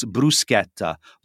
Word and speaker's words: bruschetta: but it's bruschetta. Brew, like you're brewing a coffee, and schetta, like you're sketting down a mountain. I don't know what you can use there, but bruschetta is not bruschetta.